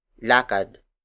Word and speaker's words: lakad: For example, lakad, lakad, lakad. Lakad is spelled as L-A-K-A-D.